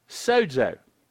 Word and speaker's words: sozo: S-O-Z-O is how it's transliterated into English, but it's pronounced with a little D sound, sozo.